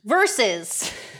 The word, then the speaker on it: Verzuz: Versus. – V-E-R-Z-U-Z. How do you say it? – Versus.